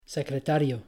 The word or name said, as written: secretario